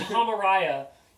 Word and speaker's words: Araya: I want Tom Araya rapping about the Holocaust.